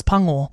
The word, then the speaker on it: pango: And I pronounced it like tango. Embarrassing. It was pango.